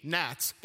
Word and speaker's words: gnats: He sent G, gnats. That was a sneaky one, silent G.